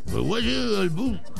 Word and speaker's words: Kahwat al-Bun: A ver, lo voy a pronunciar como debiera ser, porque tú no sabes árabe. A ver. Kahwat al-Bun.